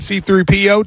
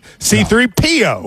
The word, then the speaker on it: C-3PO: Okay, so now isn't it interesting that R2-D2 and C-3PO, two droids, didn't like each other in real life? – Yeah, I think that's a very interesting...